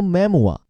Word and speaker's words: memoir: 先说 memoir，先看发音，就跟它这拼写啊没啥联系，因为这个词来自法语，means memory，最初的意思其实就记忆、回忆。